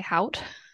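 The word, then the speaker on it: Haught: That's just how I've been pronouncing it. – Sometimes I like to spice it up a little bit and say hout.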